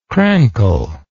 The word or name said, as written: crankle